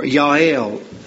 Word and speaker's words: Yael: Now I wonder if Deborah's example did not inspire Jael as well, or Yael, as we would pronounce it in the Hebrew.